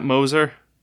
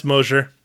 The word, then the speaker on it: Mosier: Starring Kevin Smith, Jason Mewes, Jeff Anderson, Brian O'Halloran, Mallory Gigliotti, Lisa Spoonar, Scott Mosier. Oh, I said that wrong, huh, man? Mosier? – Yeah, it's Mosier.